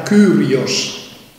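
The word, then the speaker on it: Kyrios (Κύριος): Piccola osservazione, io ho letto la parola Signore, l'ho pronunciata Kyrios, perché come si pronunciassero le vocali al tempo di Gesù, le vocali del greco al tempo di Gesù, non lo sappiamo.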